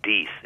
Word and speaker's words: Death: For instance, in the United States, people who have the name D-E-A-T-H, which we pronounce death. – Mm-hmm. – It's actually pronounced Deeth in the UK.